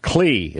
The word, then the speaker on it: Cle: Clee, is that correct? – Yes, it's spelled CLE.